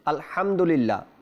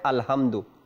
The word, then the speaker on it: আলহামদুলিল্লাহ: সূরা ফাতিহার প্রথম আয়াত যে রয়েছে আলহামদুলিল্লাহ, আলহামদুলিল্লাহ, এই হা উচ্চারণ করতে গিয়ে যখন বাংলায় লিখে তখন বলে আলহামদু, এটা গুনাহের কাজ এবং মারাত্মক গুনাহ হবে।